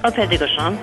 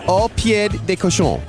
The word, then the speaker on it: Au Pied de Cochon: Au Pied de Cochon. – Au Pied de Cochon.